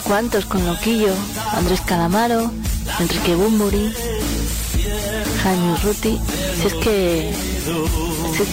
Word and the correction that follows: Urrutia: Jaime Urrutia, lo dije bien, no sé qué dije.